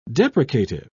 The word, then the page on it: deprecative: British English